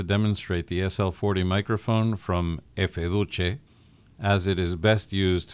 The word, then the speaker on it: Efe Duce: my contacts there have confirmed that the brand name is indeed intended to be pronounced in Italian as Efe Duce.